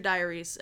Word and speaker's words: diurese: And the big question is, do they transfuse or diurese? I feel like I'm saying that wrong. – I think you got it. – Yeah. Transfuse or diurese.